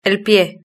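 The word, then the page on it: el pie: European Spanish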